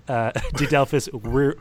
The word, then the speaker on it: Didelphis Virginiana: Latin name Didelphis Virginiana. My Latin is also very rusty, which... – Well, you see, in Latin, V's sound like W's, okay. So it'd be... Didelphis Virginiana.